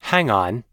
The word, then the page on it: hang on: American English